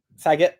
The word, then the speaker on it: Saget: RIP to Bob Sargent. I don't know how to pronounce his surname. – Saget, even. That would be the one.